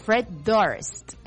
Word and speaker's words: Fred Durst: Fred Durst, ¿no? Fred Durst. Ahí está, sí lo dije bien.